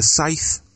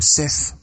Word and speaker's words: scythe: Yeah, and he kills her with I never know how to pronounce this, is it a scythe or a sith? – Scythe. – Scythe, that's it.